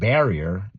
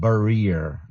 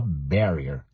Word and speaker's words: barrier: By the way, those of you learning English as a second or foreign language, remember this word barrier, that's the pronunciation. It's not barrier. No, no, no, no, no, no. Many people uh, pronounce it uh, that way, but it's not the best pronunciation for this word is a barrier, stressing the first syllable, ba, ba, ba, barrier.